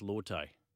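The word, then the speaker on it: Lote: I remember years ago when, you know, Lottie Tungiri said, oh, it's actually pronounced Lotte.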